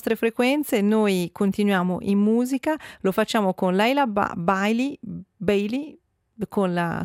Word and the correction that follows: Leila Bialy: Allora, correggo la pronuncia, è Leila Bialy, con questa sua But Not For Me, cantante canadese Leila Bialy, con questo brano tratto dal suo album Your Requests del 2023.